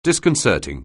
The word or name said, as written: disconcerting